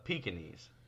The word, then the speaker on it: Pekingese: What about Pekings? I'm sorry. – What about those dogs? – Wait, did you say Pyrenees? – No, the Pekingese. The P-E, it's spelled like Peking.